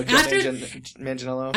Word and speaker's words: Joe Manganiello: That is still, anybody who doesn't think that they like Joe Manganiello, is that how you say his name? I can't even. – I believe so, Joe Manganiello.